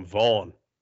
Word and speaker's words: Von: They're pronouncing V-O-N differently now that he's in L.A. They're calling him Vaughn. – Oh, it's Vaughn. – Joe Buck hits him with the Vaughn.